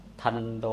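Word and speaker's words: ทัณโฑ: ทันโดอ่านว่าทันโดแปล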